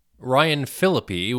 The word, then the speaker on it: Phillippe: Ryan Phillippe, which is the proper pronunciation, unlike I said earlier in the show,